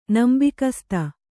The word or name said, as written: ನಂಬಿಗಸ್ತ